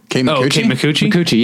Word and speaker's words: Kate Micucci: Kate Micucci.